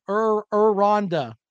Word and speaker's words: Aranda: Arenado. Sorry, Baylor man. I didn't pronounce his name correctly. Aranda. Okay. – Aranda. – His name. Aranda.